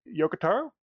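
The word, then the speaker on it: Yoko Taro: And I know Yokotaro.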